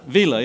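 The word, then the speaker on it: Vila: How do you say his name? Villa, that's right, Bob Villa.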